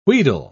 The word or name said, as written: wheedle